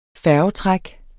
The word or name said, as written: færgetræk